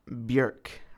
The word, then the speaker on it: Björk: It's Björk, rhymes with jerk.